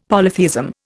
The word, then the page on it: polytheism: Indian